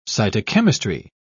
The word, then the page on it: cytochemistry: British English